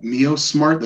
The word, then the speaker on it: MiYOSMART: We have a product called MioSmart that's spelled MIYOSMART. And it's a product that we worked on with Hong Kong Polytechnic University.